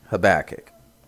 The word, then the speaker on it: Habakkuk: Yeah, and that's Habakkuk. And you're probably like, what? Yeah, that's how you pronounce